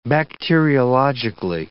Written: bacteriologically

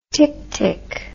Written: ticktick

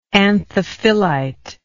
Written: anthophyllitic

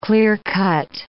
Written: clear-cut

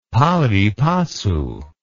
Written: pari passu

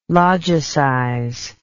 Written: logicize

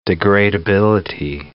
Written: degradability

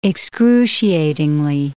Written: excruciatingly

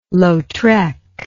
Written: Lautrec

